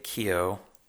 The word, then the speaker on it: Keough: Medora Sloan, played by Riley Keough.